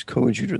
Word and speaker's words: coadjutor: But he corrected me on the term. It was coadjutor. That's how they pronounce it.